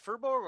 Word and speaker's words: Firbolg: Is it firbolg or firbolg? – Firbolg. – Firbolg. – Okay. I thought it was fur, like the fur. It should be fur, but like... – Well, it's a regional pronunciation.